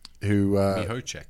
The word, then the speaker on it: Mihocek: I'm going to mispronounce his name, Mihocek. – Mihocek. – Yeah, Brody Mihocek played his first game for Collingwood and kicked four goals straight, if you don't mind, on debut.